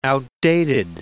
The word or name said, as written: outdated